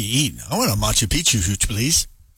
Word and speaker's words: Machu Picchu: It reaches all seven continents, and you get to see places like the Taj Mahal, the Great Wall of China, the Colosseum, Machu Picchu. I think that's how you say it. – Oh, yeah, Machu Picchu.